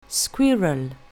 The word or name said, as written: squirrel